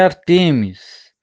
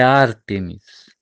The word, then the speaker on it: Ártemis: Quando eu falei Ártemis, alguém pode, por conhecer o pensamento grego, fala, não, é Artemis. Irmãos, a pronúncia transliterada para o português é Ártemis.